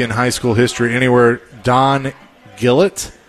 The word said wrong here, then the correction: Gullett: Gillett or Gullett? – Gullett? Gullett, okay.